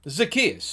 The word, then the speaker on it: Zacchaeus: I'm saying his name wrong, right? – That's all right. Well, we know who you mean. Zacchaeus. – Zacchaeus.